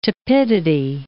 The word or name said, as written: tepidity